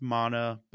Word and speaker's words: mana: I know it's really pronounced mana, but I grew up in America and have never seen that word, and now I'm trying to unlearn 30 years of RPG pronunciation, so bear with me, folks.